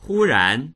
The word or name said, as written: hu1ran2